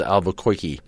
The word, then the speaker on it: Albuquerque: By the way, it's pronounced Albuquerque. – Oh, yeah, like Bugs. Yeah.